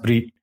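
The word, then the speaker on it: Brit (ברית): It would look like Bereth, but it's pronounced Brit.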